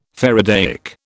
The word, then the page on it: faradaic: British English